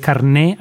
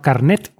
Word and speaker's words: carné: Porque aquí es carné, acabo con acento en la I, no carnet.